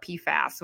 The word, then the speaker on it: PFAS: Yeah, honestly, I have not written anything about PFAS, which is how they pronounce it.